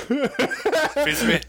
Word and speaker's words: Fils-Aimé: Fils-Aimé.